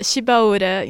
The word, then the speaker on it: Shibaura: Ficou um ano no exterior estudando na Chubara Institute of Technology, foi isso? Isso. Fala para mim direitinho como é que fala. – Uh, Shibaura Institute of Technology.